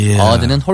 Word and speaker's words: odd: It's really important to have good pronunciation too because if your O is too strong, ode, O-D-E is another word that means like a musical poem about someone. – 맞습니다. – Not ode, but odd.